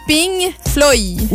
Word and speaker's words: Pink Floyd: Et là, je n'ai pas mal prononcé Pink Floyd. C'est Pink Floyd. – Oui, P-I-N-G-F-L-O-Y.